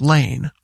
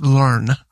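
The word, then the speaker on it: Lahn: People ask me how to pronounce my last name and it's L-A-H-N, but it's pronounced Lane.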